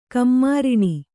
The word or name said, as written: ಕಮ್ಮಾರಿಣಿ